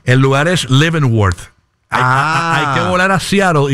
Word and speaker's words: Leavenworth: Leavenworth. – Qué bien lo pronuncias. – Leavenworth.